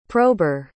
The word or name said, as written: prober